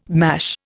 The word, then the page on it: mash: Indian